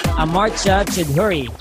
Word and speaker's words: Amartya Choudhury: Amartya, Choudhury. And Amartya, can you tell us something about yourself? – Hey there, so Amartya Choudhury, that's the pronunciation, but whatever.